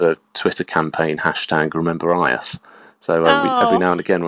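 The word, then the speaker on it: Áed: So I think one of the episodes which could have been very challenging because there was almost no information was a chap called Aeth, which is spelled A-E-D.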